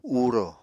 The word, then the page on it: uurò: Apatani